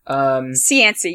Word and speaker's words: Cianci: Cianci. Did I pronounce that right? Hope I did. – Cianci. Yeah, you got it.